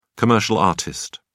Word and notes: commercial artist: British English

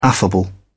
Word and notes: affable: British English

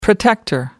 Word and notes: protector: American English

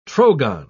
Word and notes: trogon: British English

